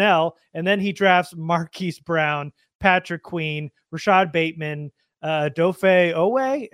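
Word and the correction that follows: Odafe Oweh: Am I saying that right? Maybe. – Odafe Owe. Yeah.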